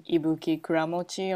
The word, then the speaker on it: Ibuki Kuramochi: So just type in Ibuki Kuramochi on Google it, so you can see it that, that is spelled.